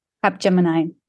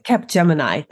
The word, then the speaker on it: Capgemini: Capgemini. – Mm-hmm. Capgemini.